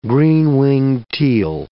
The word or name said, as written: green-winged teal